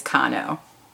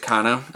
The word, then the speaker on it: Kahno: I actually think it's Kano. – Oh, is it Kano? – I mean, that's what it looks K-A-H-N-O.